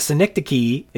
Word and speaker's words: synecdoche: A synecdoche, it's spelled S-Y-N-E-C-D-O-C-H-E, and obviously pronounced with hard Greek phonetics or something like that.